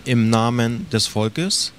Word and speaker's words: Im Namen des Volkes: Well, can you pronounce it for us first? – Okay, it's called Im Namen des Volkes, okay.